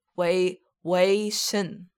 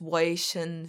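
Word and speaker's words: WayV: This is called Wavy, which is NCT's Chinese subunit, and it's pronounced Wei Shin.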